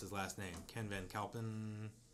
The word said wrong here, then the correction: Van Valkenburg: Van Valkenburg.